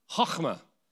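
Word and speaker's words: Chachma (חכמה): You've got to say it right in the back of your throat. Chachma, wisdom.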